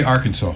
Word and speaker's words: Arkansas: Let me say Arkansas. – You're correct. Good job. Pronouncing Arkansas as Arkansas is required by Arkansas state law. If you were to pronounce it Arkansas, which is how French explorers spelled what they heard local Indians say as their name for the region, which Americans spelled as A-W, not A-S.